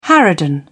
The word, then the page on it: harridan: British English